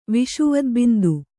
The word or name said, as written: ವಿಷುವದ್ಬಿಂದು